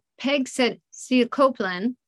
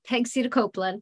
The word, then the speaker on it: pegcetacoplan: So what does pegcetacoplan do? – So pegcetacoplan, you said it correctly, it's a pegcetacoplan, blocks C3.